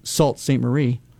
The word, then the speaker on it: Sault Ste. Marie: I thought to myself, well, maybe up north, because everyone always mispronounces it anyway. Well, at least not from this state, anyway. I mean, it's obvious. You have to call it Sault Ste. Marie.